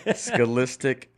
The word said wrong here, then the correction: Scholastic: Well, how do you say it? – Scholastic. – Scholastic. – Jeez. Yeah, you got to do the whole thing.